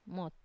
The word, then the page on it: mot: Wamesa